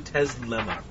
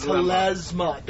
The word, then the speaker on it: Telesma: You keep saying Teslema. – It's Telesma. Don't say the thing that's wrong. – It's like talisman. – Telesma. – Not like the crazy inventor.